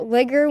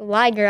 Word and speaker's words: liger: I kept pronouncing it ligger when I was like doing the research, and I'm like, no, it's a liger.